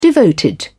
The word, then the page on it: devoted: British English